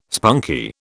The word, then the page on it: spunky: British English